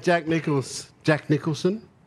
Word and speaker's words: Jack Nicholson: Yeah, old mate Jack Nicholson.